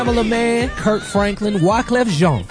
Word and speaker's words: Wyclef Jean: No, it's Wyclef Jean. – Yeah. – All right, praise the Lord. – Yeah, it's spelled like Jean, though. – It is, you know. – Yeah, J-E-A-N is Jean in America.